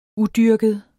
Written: udyrket

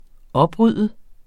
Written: OPRYDDET

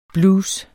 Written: blues

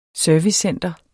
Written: servicecenter

